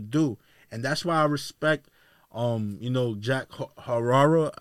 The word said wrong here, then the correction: Harlow: I'm probably saying his name wrong. That kid who came out with the time was popping. – Oh, Jack Harlow? – Yeah.